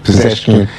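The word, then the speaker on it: Pezeshkian: Yeah, so I think that what we saw from Mr. Pezhakian, did I pronounce that correctly? – Pezhakian.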